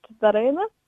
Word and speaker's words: Daryna: In Ukrainian, it's pronounced Daryna.